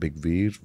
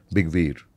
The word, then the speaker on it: Bygveer: The story of BYG came from this Nordic mythology of a guy called Big Veer, whose name is spelled as BYGVEER, Big Veer.